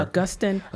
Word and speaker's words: Augustine: Augustine Classical. Rhymes with Justin.